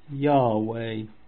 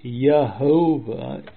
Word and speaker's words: Yahweh: By Dr. Thomas, the name of the deity was pronounced Yahweh. On this point, it is only needful to observe that an overwhelming majority of critics have decided in favour of this pronunciation. Jews and those who are not Jews concur in this. We have already observed that the pronunciation Yehovah is the result of Masoretic manipulation.